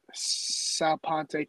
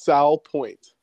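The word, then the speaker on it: Salpointe: So, I was doing some research on you, and I saw that you played 3 years at Sal Ponte Catholic. Is that how you say it? – It's Sal Point.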